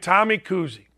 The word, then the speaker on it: Kuhse: Here's what I like about St. Mary's there's a kid named Tommy Kuhse. Tommy Kuhse, it's not Bob Cousy's son. It's not Bob Cousy's grandson. It's spelled like K U S H E.